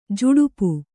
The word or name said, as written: ಝಡಪ